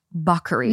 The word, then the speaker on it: Bacari: It's spelled Bakari. B-A-C-A-R-I. Bakari. But it's pronounced Bakery.